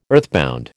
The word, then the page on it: earthbound: American English